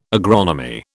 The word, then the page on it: agronomy: British English